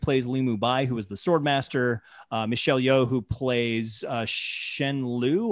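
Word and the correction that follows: Shu Lien: or Lu Shen. – Shulin. – Thank you. – I wrote it phonetically. – Thank you so much. Shulin.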